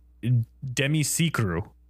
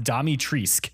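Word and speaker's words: Dimitrescu: Her name is Romanian, and it's pronounced Demisicru. That's what it's supposed to be pronounced. In the game, it's pronounced Damitrisc.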